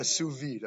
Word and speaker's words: sous vide: how do you pronounce that? Yeah, sous vide. I'm not as cultured as Jerome is. Sous vide, what do they call these things? A sous vide machine.